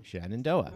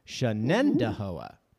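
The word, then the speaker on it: Shenendehowa: The name looks like Shenandoah, four syllables, Shenandoah. Mm-hmm. But it actually has five syllables, Shenandahoa. The accent is on the second syllable.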